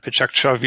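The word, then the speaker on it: Pecha Kucha: Gibt es innovative Formate, sowas wie Lightning Talks, Pechakucha oder Pechakcha, wie es offiziell ausgesprochen wird, World Café oder Open Space und solche Sachen.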